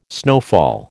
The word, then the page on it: snowfall: American English